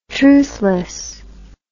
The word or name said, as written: truthless